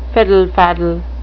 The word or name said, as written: fiddle-faddle